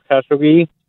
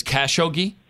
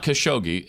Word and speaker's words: Khashoggi: It's like that, Khashoggi. – It's Khashoggi? – Yeah, there's an emphasis on like the first syllable. – So it's not Khashoggi, it's Khashoggi.